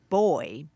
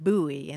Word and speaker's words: buoy: And you'll recall that her husband is British, and he said boy, boy. And she pronounced it buoy.